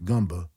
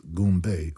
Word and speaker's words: Goombay: The technique results in a sound like gumba, which has come to be pronounced Goombay over the years.